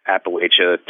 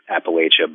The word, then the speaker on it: Appalachia: People who are further north and Appalachia tend to say Appalachia, but both are legitimate ways of saying it, and it's more of a cultural, regional type of pronunciation.